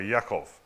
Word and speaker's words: Yaakov: So he was named Jacob, or Yaakov, in their pronunciation.